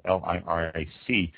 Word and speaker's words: Lirac: Costière de Nîmes, spelled Costière de Nîmes, and Lirac is Liric.